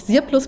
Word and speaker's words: SIRPLUS: Die haben sich, also das ist von Sirplus, Sirplus, glaube ich, spricht man sie aus.